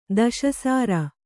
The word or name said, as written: ದಶಸಾರ